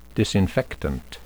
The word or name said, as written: disinfectant